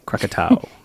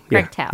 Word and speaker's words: Krakatoa: And as the name implies, it's a water park centered on a 200-foot-tall volcano named Krakatoa. Didn't I pronounce that right? – Krakatoa. – Krakatoa. – Yep.